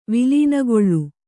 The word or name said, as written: ವಿಲೀನಗೊಳ್ಳು